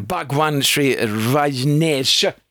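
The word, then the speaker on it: Bhagwan Shree Rajneesh: Eh, Bhagwan Sri Rajneesh.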